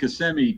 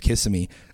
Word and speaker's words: Kissimmee: In the city of Kissimmee doing a little landscaping.